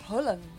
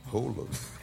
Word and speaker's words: Haaland: É, mas a pronúncia certa é Haaland. – É, Haaland.